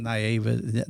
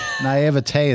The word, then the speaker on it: naivety: And I think my naivety was, did I say that right? – Naivety. – My, my na- naivety. – naivete is how people say it, but it's you you you were being naive.